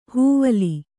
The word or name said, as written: ಹೂವಿಲ್ಲು